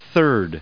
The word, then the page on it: third: American English